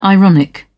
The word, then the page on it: ironic: British English